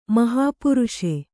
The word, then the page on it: ಮಹಾಪುರುಷೆ: mahāpuruṣe